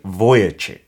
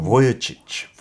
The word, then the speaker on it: Vujicic: It is Nick Vujicic. Oh. 있잖아요. 닉 브이치치라고 알고 있는 인물인데. – 오, 네, 영국용으로 보여치치, 보여치치.